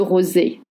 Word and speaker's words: rosé: The most ubiquitous one is le rosé. Le rosé, not rosé, not rosé, rosé. Rosé, I know it's so hard to pronounce, you want to make that Z sound.